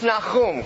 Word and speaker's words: Nahum: It is pronounced Nahum. Can you say that with me? Nahum. It's not Nahum. Nahum. That is so Western Americanized.